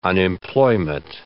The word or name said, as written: unemployment